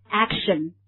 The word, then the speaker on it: action: action, 对不对，比较的往下压的感觉这个我们汉语当中几乎没有这样类似的发音，我们再来发一遍 action, OK, 好，那么这个地方我们来看一下它的这个 everyday expression, 就是它我们穷尽这个 action 这个核心语汇的所有层次的意思。